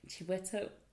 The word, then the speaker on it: Chiwetel: His name is Chiwetel Ejiofor. Chiwetel. Did I say it wrong? – Chiwetel. – Chiwetel. It begins with an L.